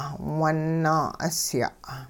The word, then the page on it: wun-naa'usya': hupa